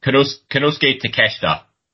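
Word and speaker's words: Konosuke Takeshita: Kenosuke Takeshita. I think that's how you pronounce it. – It's spelt take shit. You know what I mean? Like, it's all I can do not to fucking say it like that.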